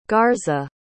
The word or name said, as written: garza